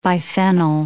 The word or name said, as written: biphenyl